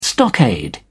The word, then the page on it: stockade: British English